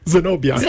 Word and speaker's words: Zenobia: William Zambodia. – Zenobia. – See? I can't even pronounce like, so, it. – It's okay. I've been called Zamboni and everything Zenobia.